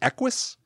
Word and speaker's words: Equus: Equus? – Yes.